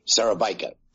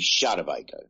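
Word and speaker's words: Szarabajka: Keith Sarabakada. – Sarabika. – Sarabika, sorry. you want to be Polish, it'd be Shadabika.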